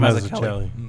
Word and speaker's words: Mazzucchelli: I know I just butchered that. – I think it's Mazzucchelli. – That's good. That's how he said his name.